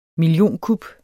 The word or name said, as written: millionkup